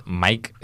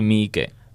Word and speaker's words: Miike: No es Mike, es tal cual. Tienes que leerlo tal cual se dice. Se lee igual Takashi Miike.